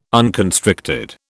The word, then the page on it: unconstricted: British English